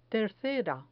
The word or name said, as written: tercera